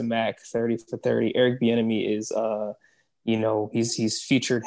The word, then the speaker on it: Bieniemy: Let me make sure I got his name right. Eric, was it Benemy? I say it last time. Eric Bieniemy. Eric Bieniemy.